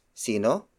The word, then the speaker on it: Sino: Word for word, it's Sino to. Sino? To. Sino? To. Sino is spelled as S I N O. It means who. That's the Tagalog word for who.